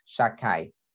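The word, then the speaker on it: Shaddai: This is one of the names of Hashem, so we don't pronounce it the way it's written, Shakai. Okay,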